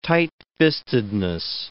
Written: tightfistedness

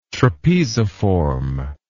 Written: trapeziform

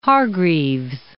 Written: Hargreaves

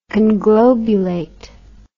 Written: conglobulate

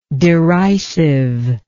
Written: derisiveness

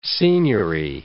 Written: signory

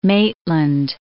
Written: Maitland

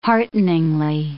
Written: hearteningly

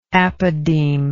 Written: apodeme

